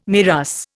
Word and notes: MIRAS: Indian

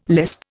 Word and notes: lisp: Indian

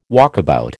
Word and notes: walkabout: American English

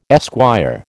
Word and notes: esquire: American English

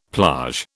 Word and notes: plage: British English

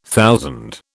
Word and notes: thousand: British English

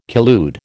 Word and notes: collude: American English

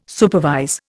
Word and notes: supervise: Indian

